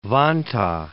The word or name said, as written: Vantaa